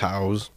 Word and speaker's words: Taos: Taos. I would say Taos. – Taos. Okay, we'll say Taos for now.